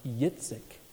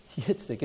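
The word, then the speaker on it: Yitzik (יצחק): In Hebrew, that name his mom and dad gave him is pronounced Yitzik. Yitzik. It even sounds like a chuckle, doesn't it?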